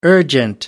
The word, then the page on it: urgent: American English